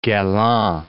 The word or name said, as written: Galland